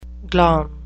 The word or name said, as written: glom